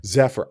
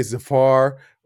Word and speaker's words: Zephyr: Zephyr. I don't know why I want to say Zafar or Safari or whatever. It's literally spelled phonetically.